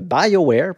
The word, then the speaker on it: BioWare: Ce jeu-là est sorti, euh, a été développé plutôt par euh, BioWare, puis euh, BioWare plutôt, hein, je vais le prononcer de la bonne façon.